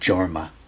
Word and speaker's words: Jorma: Again, a Jorma, I think it's with a J, it's not Jorma, might be Kaukonen. I think his wife pronounces it Jorma, so that's got to be the right pronunciation.